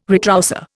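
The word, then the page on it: retroussé: Indian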